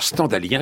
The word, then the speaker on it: stendhalien: Alors, stendhalien, il faut bien prononcer comme ça, parce que ça se prononce comme scandalisé.